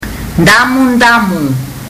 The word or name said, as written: damudamu